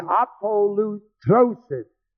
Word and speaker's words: apolutrosis: Being justified freely, by his grace, through the redemption, the word redemption is the Greek word apolutrosis, spelled A-P-O-L-U-T-R, long O-S-I-S.